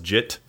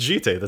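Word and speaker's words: Jitte: Umezawa's Jit, for example, just that one always makes me cringe. Because it's Jite, that's an actual word.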